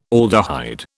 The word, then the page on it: aldehyde: British English